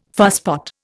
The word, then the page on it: fusspot: Indian